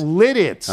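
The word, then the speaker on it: Lititz: That's Lidditz. I kept saying Lilitz earlier in the show with Will Bond. – And it's Lidditz? – It's Lidditz. Oh.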